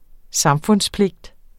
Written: samfundspligt

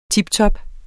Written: tiptop

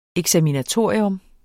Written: eksaminatorium

